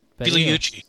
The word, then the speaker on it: Pigliucci: Pigliucci would be my take on it.